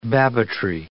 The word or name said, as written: Babbittry